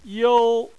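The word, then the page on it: you: tone 1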